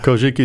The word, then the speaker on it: Kojiki: Yeah, Kojiki is an ancient text that tells a creation story from the Japanese Shinto viewpoint.